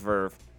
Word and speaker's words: Verve: Who's new, who don't know what Verve is, it's VRV. That's what everybody thinks that it's called, but it's actually pronounced Verve.